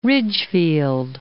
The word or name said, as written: Ridgefield